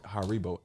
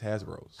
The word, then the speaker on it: Haribo: I would say, you right, Haribo. I don't know why I always pronounce these Hasbros.